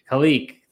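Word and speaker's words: Khaleke: which is Kaliki Hudson, if I'm even saying it correctly. Kaliki. Thank you.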